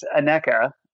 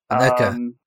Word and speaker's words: Aneka: So the company is Aneka. – Yeah, it's Aneka. Aneka. But that's okay. Everyone kind of mispronounces it.